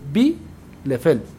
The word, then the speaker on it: Bielefeld: Entonces, cuando tienes una I y una E, suena I. Entonces se dice Bielefeld.